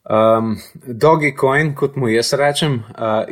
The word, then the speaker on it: Dogecoin: Prvo, uh, Dogecoin oziroma Dogecoin, ne vem točno, kako se izgovori. Uh, Dogecoin ali pa Dogecoin. Ja, Dogecoin.